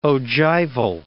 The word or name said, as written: ogival